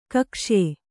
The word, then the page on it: ಕೌಕ್ಷೇಯ: kaukṣēya